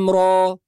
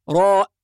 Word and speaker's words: را: والخطأ الذي يقع في بعض الناس أنه يقول راء بهمزة، وهذا خطأ، فالراء في القرآن تنطق را را، يعني را ألف فقط.